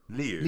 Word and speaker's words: Lieu: Well, it's L-I-E-U. – Liu? – Liu.